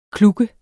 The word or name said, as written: kluk